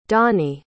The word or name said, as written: dani